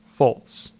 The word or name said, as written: Fults